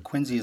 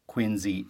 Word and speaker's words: Quincy: that the proper pronunciation of Quincy is Quincy and not Quincy, even though it is spelt Quincy.